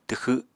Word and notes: twxw: apatani

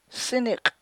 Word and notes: sinik': chukchansi

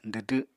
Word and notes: dwdw: apatani

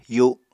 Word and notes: yu7: apatani

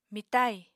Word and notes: mitai: Apatani

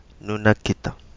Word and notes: Nunakita: Kapingamarangi

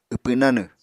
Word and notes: wpw nanw: Apatani

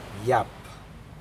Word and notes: yap: Aneityum

